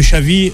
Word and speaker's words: Xavi: Et l'arrivée de Xavi, pour le prononcer en espagnol, a su redonner à Barcelone, on va dire, ses lettres de noblesse.